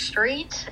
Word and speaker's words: Street: My name is Alex Street, A-L-E-X, last name S-T-R-E-E-T.